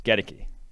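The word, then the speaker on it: Goedeke: I looked up the pronunciation of some guys. Getteke, which still doesn't make sense to me.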